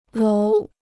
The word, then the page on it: 楼: lóu